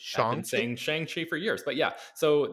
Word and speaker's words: Shang-Chi: So I think it's just like like that's how you read it, you know, in a English, a Western, yeah, from a Western perspective. But Shang-Chi is evidently the correct pronunciation.